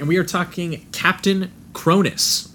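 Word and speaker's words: Kronos: I think we'll just jump right into Captain Cronus is how they say it in the movie. But I think that Canadians say it Cronos. – Yeah, that's just how it's spelt. So I read it Captain Cronus when I first read it. But every time they pronounce it in the movie, they say Cronus.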